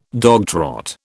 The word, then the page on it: dogtrot: British English